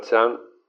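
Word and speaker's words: chan (禪): Originalmente chan, la palabra chan, se pronunciaba en chino dian, pero con el tiempo esta pronunciación cambió a chan.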